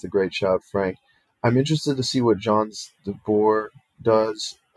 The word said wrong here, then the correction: Ons Jabeur: Did I just spell that? Did I say that? Oh my God, right? – Ons Jabeur. – Ons Jabeur. I am so sorry.